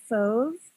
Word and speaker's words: Fauve: So, um, the other band that I discovered that I think is kind of interesting is this band Fauve, and they're a French band.